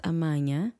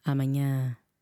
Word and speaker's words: amanhã: Accent on the last A of amanhã, not amanhã. Amanhã.